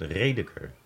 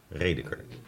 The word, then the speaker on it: Radeker: Hoe spreek je dat uit? Radeker. Radeker. – Ja, maar niet te Duits liever.